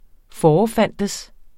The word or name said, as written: forefindes